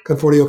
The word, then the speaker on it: Conforti: Conforti.